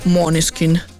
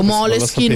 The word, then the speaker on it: Måneskin: Avevo scoperto tra l'altro che si legge tipo Måneskin nel modo giusto, tipo Måneskin, ma con la N.